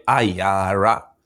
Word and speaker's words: Iyara: It's like your name is like people call me Iyara. Some say Ira, some all kind of name.